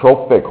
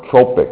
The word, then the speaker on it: trophic: And I see a number of trophic hormones, or tropic, it can be pronounced either way and spelled either way.